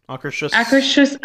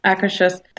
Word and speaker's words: Akershus: Yeah, I don't think they need to go to Acker House. How do you say it? I always... Akershus. – I mean, I don't know how it's pronounced in Norwegian, but Akershus.